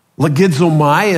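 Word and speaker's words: legizomai (λογίζομαι): Now, this term to regard, legizomai as it's pronounced in the Greek, this is a word from which we get the English word logic.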